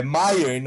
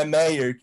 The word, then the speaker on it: Meyer: E Deminha, antes de passar para você, só deixa eu pronunciar certo o nome do nosso querido Urban. É Mayer, não é Meyer, que nem eu falei.